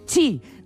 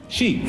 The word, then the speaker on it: Xi: Bueno, recordemos que el nombre de pila no es Chi. Rajoy nos recuerda cómo se pronuncia. – Xi.